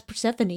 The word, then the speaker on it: Persephone: When I saw when I first saw that tweet, I was in Nashville and I asked my brother, "How would you pronounce this?" And he says, "Oh, that's Persephone," and he went on to talk about something Greek.